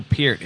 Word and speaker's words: Peart: No one, they say it wrong in movies and stuff too. – Yeah. – They always talk about it. Gaffs like School of Rock, they call them Pert.